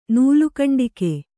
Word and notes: ನೂಲುಕಂಡಿಕೆ: Kannada

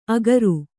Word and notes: ಅಗರು: Kannada

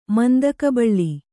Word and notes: ಮಂದಕಬಳ್ಳಿ: kannada

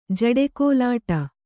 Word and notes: ಜಡೆಕೋಲಾಟ: jaḍe kōlāṭa

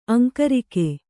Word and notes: ಅಂಕರಿಕೆ: aŋkarike

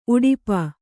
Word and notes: ಉಡುಪ: kannada

